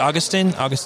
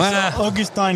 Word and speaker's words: Augustin: And do I pronounce it Augustin? – You can say whatever you want. It's Augustin in French, but it's hard to say in English. So you can say Augustin or even Gus.